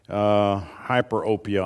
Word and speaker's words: hyperopia: Myopia is a nearsightedness, and then there's a hypermyopia type of situation. Hyperopia, I think, is the way it's pronounced.